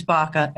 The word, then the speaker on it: Baca: It is Baca, and it's potentiation process.